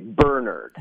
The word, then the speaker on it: Bernard: You know, I talked to Bernard and Avis's last surviving son, Mark DeVoto, who is 82 years young, and he told me it is pronounced the English way, Bernard.